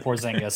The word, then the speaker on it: Porzingis: You said it right when you said Porzingis, so we'll go with that.